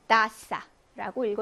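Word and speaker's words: taza: 따싸라고 읽어주시면 됩니다. 번데기 발음 혹은 편하게 S 발음해 주시면 되고.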